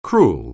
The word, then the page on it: cruel: anglais